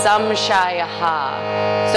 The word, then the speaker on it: samshayaha: An extra syllable, so it ends up being samshayaha.